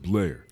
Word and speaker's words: Blair: You pronounce it as just Blair. Yeah, just okay? pretend it's a regular Blair.